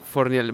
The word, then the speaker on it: Fornieles: Fornieles. – Vale, Fornieles como suena.